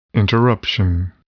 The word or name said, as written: interruption